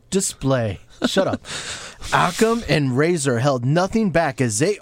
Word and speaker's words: Rezar: Is it Razor or Razor? – R-E-Z-A-R. Pronounce Razor. Razor? How did they say it? – I thought it was Razor.